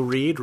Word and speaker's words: Reid: Yeah, you can find me on Twitter at Joe Reed, Reed spelled R-E-I-D.